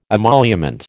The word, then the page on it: emolument: American English